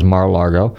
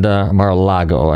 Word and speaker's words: Mar-a-Lago: Of course, in this case, it was Mar-a-Lago. And Mar-a-Lago, I should say. I always say it wrong.